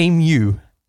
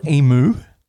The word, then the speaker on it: emu: an emu, not an emu. They don't make a emu. mooing sound. Emu. – I did mispronounce that.